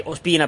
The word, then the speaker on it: Ospina: Che Ospina, presidente, l'ho detto giusto stavolta, eh? – Sì.